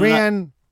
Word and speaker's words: Rian: We've done Rian Brewster. – Yeah, Rian, a bit like Ian. – You know, I understand the idea. It rhymes with Ian.